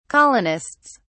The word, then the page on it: colonists: British English